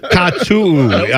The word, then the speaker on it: Fakatu'u: Fakatu'u. I'm saying a very long, drawn out. That's the sound the kids use on TikTok. The first like syllable of his name is just like FAH, which is what is on, like, I'm not going to say it, but you've heard it.